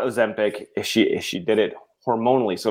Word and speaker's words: Ozempic: How do you say it? – Ozempic. – Is that right? Ozempic? – Yeah, okay. You got it.